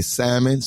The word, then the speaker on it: salmon: Because isn't the salmon. L? – I think the L is silent. – Yeah. – If I'm not mistaken. – I think salmon. It is. Yeah, yeah, I think it's silent.